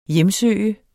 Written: hjemsøge

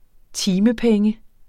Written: timepenge

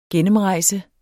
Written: gennemrejse

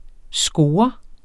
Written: SCOREN

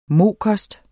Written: mokost

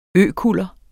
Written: økuller

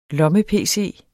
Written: lomme-pc

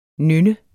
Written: nynne

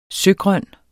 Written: søgrøn

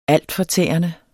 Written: altfortærende

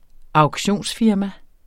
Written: auktionsfirma

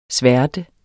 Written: sværte

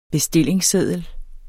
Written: bestillingsseddel

